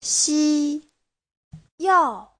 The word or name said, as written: xi1yao4